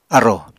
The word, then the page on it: arrò: Apatani